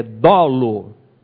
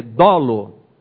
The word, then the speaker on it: dolo: Segunda atitude, é um termo que os juristas gostam, e a pronúncia correta é com a vogal aberta, é dolo. Né? Pronúncia assim para quem é nordestino, aquele ó, que abre a boca, é dolo.